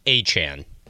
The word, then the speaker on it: Achane: They say Achane. A-Y-Chan. – Achane. Achane. Achane. So Achane. Achane? – Achan, A-Y-C-H-A-N. That's how they have it officially like listed phonetically for pronunciation. So I'm going with Achan.